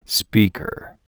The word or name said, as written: speaker